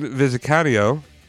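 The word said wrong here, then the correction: Vizcaíno: That's not think how you say it. Vizcanio, sure. yeah. Uh, Vizcaino, sure.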